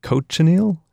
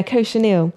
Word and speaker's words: cochineal: This is like cochineal. How do you say? – Yeah, cochineal.